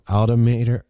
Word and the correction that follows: alma mater: Wait, what's your college? – Alma mater. Alma mater.